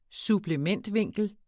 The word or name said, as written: supplementvinkel